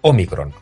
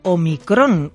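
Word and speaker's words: ómicron: que no necesita comillas y que es esdrújula, Omicron. Y como es esdrújula, lleva tilde. – Por tanto, no es, como hemos podido ver y escuchar, Omicron, con el acento en la última sílaba. – No es Omicron. Es cierto que se acentúa así muchas veces, pero esta letra griega es Omicron, que es como figura en el diccionario académico.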